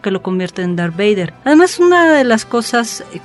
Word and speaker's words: Vader: Y Vader, Vader, si lo convertimos en alemán, le quitamos la T y se pronuncia así, Vader.